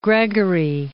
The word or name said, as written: Gregory